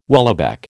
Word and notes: whaleback: American English

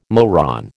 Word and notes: moron: American English

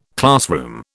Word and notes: classroom: British English

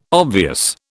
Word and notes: obvious: British English

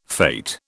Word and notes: fate: British English

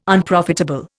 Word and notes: Unprofitable: Indian

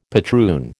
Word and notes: patroon: American English

